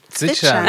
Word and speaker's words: Zwitschern: Und äh, was sind die Vögel hinten, die, ne, die am Singen oder ich kann dieses Wort nicht aussprechen, zwitschen. – Zwitschern, Zwitschern. ja.